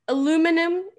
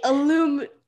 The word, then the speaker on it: aluminum: But aluminum is what people say in the States. But if you have a few mates from Britannia, they say aluminium.